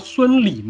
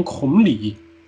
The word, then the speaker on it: 孙礼: 这个方面其实老《三国演义》也犯过错误，就是曹魏不是有个人叫孙礼吗？礼节的礼，孙礼。我老《三国》里边。魏宗万老师演的司马懿把他念成孔礼，孔子的孔，我估计就是剧本字写的比较飘，把孙猴的孙看成孔子的孔了，叫孔礼。